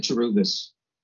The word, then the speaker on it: Cerberus: So this guy says, like, Cerberus in Greek mythology. Is that how you pronounce it? – Cerberus. – I'm not well read. How do you say it? – I like that better, Cerberus. – Cerberus? Okay. Yeah. I like Cerberus.